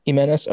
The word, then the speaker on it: Giménez: I'll probably butcher the rest of these names, but Andrés Giménez, I think I said that right. Ranks Giménez.